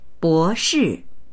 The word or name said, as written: bo2shi4